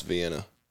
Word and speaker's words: Vienna: It, and I went home happy and cooked me some Vianney weenies. – That's amazing. – If you ain't had cooked Vianney weenies. – I can't say that I have. – See, I haven't. – Also, it's pronounced Vienna.